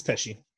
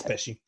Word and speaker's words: Pesci: Is it Pesci? – It's Pesci. Okay. It's Pesci. – Cool. – I mean, I hope it is. Like, I've only ever heard it being pronounced Pesci, so at this point, it's too late.